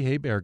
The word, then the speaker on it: Hebert: Bobby Hebert got a mention on Seinfeld. Remember George Costanza said the H is silent.